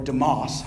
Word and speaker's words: Demas: He selected this man named Demas, D-E-M-A-S, or Demas, however you might want to say that. I only heard it pronounced Demas, to be his close companion in the ministry.